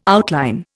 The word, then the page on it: outline: Indian